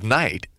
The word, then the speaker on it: knight: The kind of knight that is walking toward pole, vole, and bumbler is spelled K-N-I-G-H-T. The letter K at the beginning of the word is silent. We don't pronounce it.